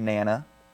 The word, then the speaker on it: Nana: it says the pronunciation is capital N, capital A, dash, lowercase N, lowercase A. So, I think that's Nana.